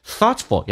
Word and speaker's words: thoughtful: Thoughtful 要记住啊， thought t 可以不发音，而且 ful 直接可以发成 fl，叫 thoughtful。